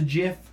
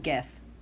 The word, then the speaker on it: GIF: On the GIF, the JIF. How do you pronounce it, GIF or JIF? – GIF. – I say GIF as well. I've heard JIF and I'm like, that's peanut butter.